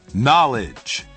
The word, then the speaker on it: knowledge: Knowledge. – Knowledge. – Knowledge. Both the K and the W are silent.